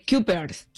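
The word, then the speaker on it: Cuypers: Es C-U-Y. – Kuipers.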